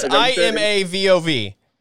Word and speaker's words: Imavov: It's Imavov. – Imavov. That sounds right. We'll go with Imavov. Okay, this is a close matchup. I could see either guy winning. I'm gonna go with Imavov.